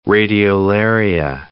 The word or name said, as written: radiolaria